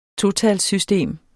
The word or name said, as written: totalssystem